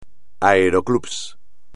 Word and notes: aeroclub: espagnol

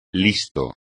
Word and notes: listo: Spanish

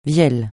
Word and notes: vielle: francais

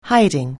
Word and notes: hiding: anglais

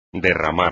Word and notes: derramar: espagnol

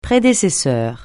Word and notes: prédécesseur: francais

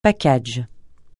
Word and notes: package: francais

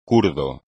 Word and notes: kurdo: Spanish

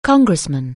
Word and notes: congressman: anglais

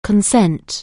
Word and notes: consent: anglais

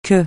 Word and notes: que: francais

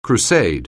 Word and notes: crusade: anglais

